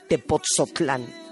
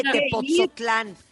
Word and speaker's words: Tepoztlán: Pero en redes sociales, para que lo vean, es Pastorela de Tepoztlán. ¿Lo dije bien, Rebeca Muñoz? – Muy bien, lo dijiste. Muy bonito. Muy bonito. – Pastorela de Tepoztlán.